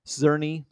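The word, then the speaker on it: Czerny: Of course, Tom Cruise, Haley Atwell, Ving Rhames, Simon Pegg, Rebecca Ferguson, Vanessa Kirby, Henry Cezanne. Zerny, sorry, I mispronounced that one, as Eugene Kittredge, who is back from the first Mission Impossible in 1996.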